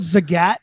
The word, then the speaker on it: Zagat: Wait, it's called Zagat, not Zagat. – Yeah, you got it. – Oh man, I've been pronouncing it wrong.